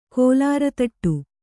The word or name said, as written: ಕೋಲಾರತಟ್ಟು